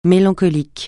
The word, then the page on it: mélancolique: francais